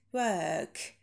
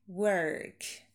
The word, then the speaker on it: work: Шва, долгий work, или work с ротированным r, work.